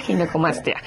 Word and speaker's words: ginecomastia: Para empezar, la palabra, le decía, ¿cómo se pronuncia? Ginecomastia, ginecomastia. – Sí, exacto. Es ginecomastia.